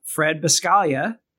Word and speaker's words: Fred Buscaglia: I'm excited to have Fred Buscaglia as my guest here on the Anti-Architect Podcast.